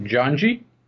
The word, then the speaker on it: Janji: It's called, hopefully I'm pronouncing it right, Janji? – Dead on, yeah.